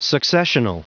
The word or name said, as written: successional